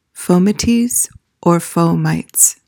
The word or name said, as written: fomites